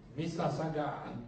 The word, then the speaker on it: Mississauga: It's spelled Mississauga. That's pronounced Mississauga. Funny way, eh?